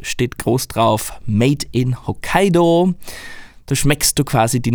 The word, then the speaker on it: Hokkaido: In Hokkaido. Nicht Hokkaido übrigens, wie es in Österreich gern mal ausgesprochen wird, ähm, Hokkaido ist Japans zweitgrößte Insel und ihr Name bedeutet übersetzt Nordmeerstraße.